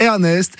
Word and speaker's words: RNST: Et l'artiste, c'est Ernest.